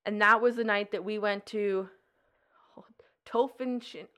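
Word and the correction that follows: Torphichen: How do you say that one? The T one. Tofenshin. – Torfiken, there you go.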